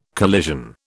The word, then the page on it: collision: British English